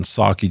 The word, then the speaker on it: Psaki: Remember, the P is silent in Psaki, just like the P in President Biden.